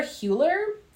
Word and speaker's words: Hüller: Um, Sandra Hüller is the is the U with the two dots. Is that how do you say that? – Is that Ooh, like ooh, oh oh.